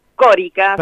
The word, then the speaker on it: Córica: Córica, acento en la O.